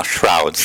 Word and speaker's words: Shrouds: Però devo dire che quest'ultimo film che è uscito da qualche giorno, che adesso lo pronuncio male, tu mi devi correggere, The Shrouds. – No, Shrouds. – Shrouds, vedi, lo dicevo già malissimo, veramente.